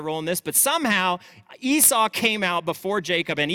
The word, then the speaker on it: Esau: So it's like this kind of, oh, yeah, wow, that's a hairy baby. What, did you say his name was Hairy? Oh, no, I thought you said Hairy. It's actually Esau, the Hebrew word. So she names him almost immediately.